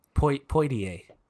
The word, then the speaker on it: Poitier: Was it Sidney Poitier? Mm-hmm. I don't know how to pronounce his last name. – Yeah, you were close. – Poitier.